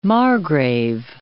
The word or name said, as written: margrave